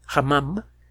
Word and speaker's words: Hammam: Se llama Tel el Hamán, Hamam. Eh, se escribe Tel, T-E-L-L, espacio E-L, guión, H-A-M-M-A-M. Eh, Tel o Tal el Hamam se encuentra en Jordania.